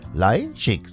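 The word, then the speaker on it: Leischik: Julia Leichig, Leichig, so wird sie ausgesprochen.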